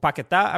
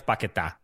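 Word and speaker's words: Paquetá: The, the, uh, it's Paqueta, not Paqueta. Um, so how do you know? because he has a, the accent mark on his name. Does he? He does, and that's how it's pronounced.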